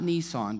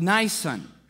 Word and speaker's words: Nisan: In the month of Nisan, it's not Nisan, by the way. It's not pronounced that way. No car companies here in the Bible. In the month of Nisan,